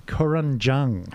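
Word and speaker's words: Kurunjang: Kurunjung. – I'll spell it for you. It's K-U-R-U-N-J-A-N-G.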